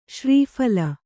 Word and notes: ಶ್ರೀಫಲಿ: śrī phali

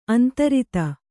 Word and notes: ಅಂತರಿತೆ: antarite

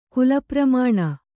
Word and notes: ಕುಲಪ್ರಮಾಣ: kulapramāṇa

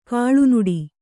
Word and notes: ಕೀಳುನುಡಿ: kīḷunuḍi

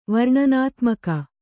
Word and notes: ವರ್ಣನಾತ್ಮಕ: varṇanātmaka

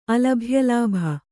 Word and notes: ಅಲಭ್ಯಲಾಭ: kannada

